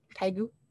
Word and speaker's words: Daegu: Oh, I said it wrong. Say it again. – Daegu. Yep.